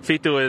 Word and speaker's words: fitu: So Fitu is how you say 7 in Tongan.